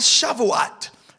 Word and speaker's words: Shavuot: And then it was also known as Shavuot. Everybody say Shavuot. And this is spelt S, this is a Hebrew word, S-H-A-V-U-O-T.